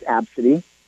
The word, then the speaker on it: Abcde: Her name is Absidy. It is spelled A-B-C-D-E.